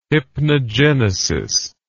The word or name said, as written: hypnogenesis